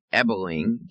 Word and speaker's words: Ebeling: It's Mick Ebeling, E-B-E-L-I-N-G, no R in there.